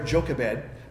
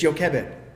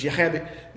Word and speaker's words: Jochebed: But I've always called her Jochebed, but that's not how you say her name. It's Jochebed. And in fact, it's Hebrew. So it's Jochebed. There's a ch in there that I can't even say.